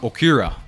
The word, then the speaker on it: Okira: Y de ahí tenemos otra herramienta que me gusta mucho, que se llama Okira.